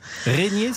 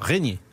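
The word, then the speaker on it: Régnié: Régnier, ça s'écrit comment? R-E-Y-N-I-E. – R-E-G-N-I-E. – D'accord, Régnier.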